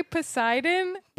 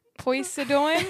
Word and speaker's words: Poseidon: and then you you were trying to say. Poseidon, but you said like Poisidon.